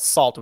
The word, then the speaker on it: Sault: I first was turned on to her through her collaborations with this group called Salt, which is pretty. They're very mysterious. It's spelled S-A-U-L-T.